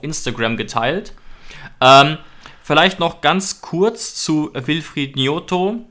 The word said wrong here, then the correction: Gnonto: Gnonto. Ich behaupte jetzt einfach mal, dass man ihn so ausspricht. Das wäre die italienische Aussprache, Gnonto wahrscheinlich. Mhm.